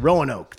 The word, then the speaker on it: Roanoke: Roanoke.